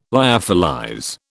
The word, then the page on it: lyophilize: British English